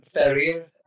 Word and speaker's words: Ferrer: Ferrer? Is that how you say it, Roberto? – Come again? – It's Ibrahim Ferrer. I can't roll the R, sorry.